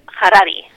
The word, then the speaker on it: Yuval Noah Harari: Yuval Noah Harari. – Harari, sí.